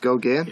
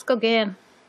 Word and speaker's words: Gauguin: Oh, it's Gauguin? Yeah, oh, thanks. – French is weird. There's bizarre pronunciations. – All right, and they love to guillotine the ends of words off. – That too. Okay. – So it's not Gauguin? – No, it's Gauguin.